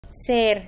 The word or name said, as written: ser